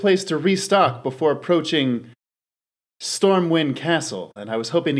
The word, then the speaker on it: Stormwind: It's Stormwind, right? I'm saying that right? Stormwind? – Yeah.